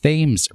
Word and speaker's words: Thames: Gillette Castle is situated on the Connecticut River between that river and the Thames River. It is pronounced that way in Connecticut, and next to a town of particular infamy.